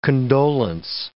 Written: condolence

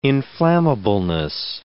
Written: inflammableness

